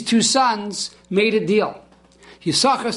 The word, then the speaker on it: Yisachar: Now Yisachar is spelled Yud, Shin, and a second Shin. But the second Shin is silent, we do not pronounce it.